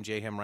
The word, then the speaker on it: J-Ham: Do the J-Ham Rhymes with Mayhem? – Yeah. – That was the old Twitch, like, Devolver Public Access, like, yeah. You know, Twitch streaming intro. Hi, I'm J-Ham Rhymes with Mayhem,